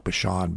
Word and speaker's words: Bashan: And by the way, I looked up how to pronounce Bashan. Some people say Bashan. I like Bashan better.